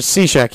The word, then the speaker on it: Cishek: Cishek. – I always want to say it, but then I look at it and I'm like, It's spelled odd. – Yeah, it is. But Cishek, he's going to retire.